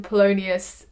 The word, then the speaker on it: Polonius: I can't pronounce his name. Is it Polonius? There you go.